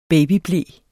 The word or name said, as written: babyble